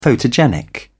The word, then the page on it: photogenic: British English